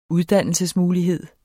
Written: uddannelsesmulighed

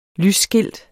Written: lysskilt

